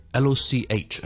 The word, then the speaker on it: loch: 而呢个音咧，我哋喺有啲字都会见到嘅，譬如我哋苏格兰咧，我哋讲一啲湖咧 LOCH 啊，我哋叫做 loch loch loch。都是有呢、這個呢、這個音嘅，OK。而呢個音發音嘅位置其實咧，同我哋 K 音嘅位置好近嘅。